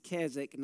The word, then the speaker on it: Keswick: It is pronounced Keswick, not the W is silent.